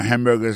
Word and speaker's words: hamburger: On a remarqué que dans le mot hamburger, ça a comme d'habitude, il y a trois syllabes là-dedans, quand on appuie et puis plus ça, c'est le premier syllabe là.